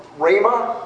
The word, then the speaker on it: Ramah: He was scattered, and he lived in the town of Ramah or Rama. I've heard them both ways pronounced.